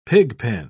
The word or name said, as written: pigpen